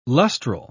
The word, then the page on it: lustral: British English